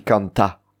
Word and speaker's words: canta: On ne dit pas, pour chanter, on dit canta. Et l'accent est sur le ta.